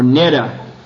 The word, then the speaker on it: Neda: The Nedas, or Neda, in Bengali is pronounced Neda.